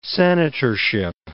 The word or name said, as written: senatorship